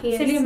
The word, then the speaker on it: Cillian: Cillian Murphy and Jamie Dornan.